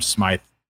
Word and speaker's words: Smythe: Like, unless you count Durham Smythe, I don't even know if that's how you pronounce his last name correctly, but very well pronounced. You skipped a Conquo, but Smythe you did all right with.